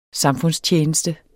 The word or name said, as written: SAMFUNDSTJENESTE